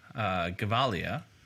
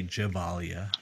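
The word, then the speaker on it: Gevalia: So, I am drinking a Swiss coffee, Gavalia. I think that's how you pronounce it. try not to be American and say Javalia. But it's a Javalia, a single origin Costa Rican coffee.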